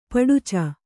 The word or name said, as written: ಪಡಚ